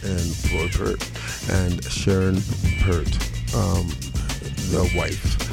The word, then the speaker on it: Peart: Nigel Peart. – Peart.